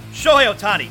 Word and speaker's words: Shohei Ohtani: Shohei Ohtani, now a Dodger, and that's the reason for the cheering. – Oh, that's terrible. It's not a big deal, but that's terrible pronunciation. Kirk, come on. Shohei Ohtani.